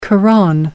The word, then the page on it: quran: British English